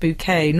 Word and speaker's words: Bucket: I just realised Hyacinth Bucket wants her last name pronounced bouquet, not just because bucket is a vulgar word, but because a hyacinth is a type of flower.